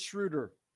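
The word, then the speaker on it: Schröder: And the Lakers they're in a stage right now where their confidence is so visible, whether it's Dennis Schroeder or is it Schroeder? Is it Schroeder? – It's Schroeder.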